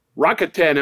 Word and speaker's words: Rakuten: Rakuten, am I saying that right, Joe? – Yes, you are.